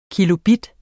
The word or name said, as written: kilobit